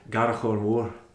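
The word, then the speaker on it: Garbh Choire Mòr: It's pronounced locally and has always been pronounced locally as Garrochor Moor.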